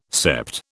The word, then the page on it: sept: British English